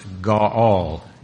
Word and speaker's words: Gaal: The word is pronounced Gaal, G-A-W-A-L. I think it's spelled G-A-A-L, but Gaal.